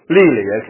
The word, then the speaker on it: Lely: How do you say his name? Lely. Okay, Lely.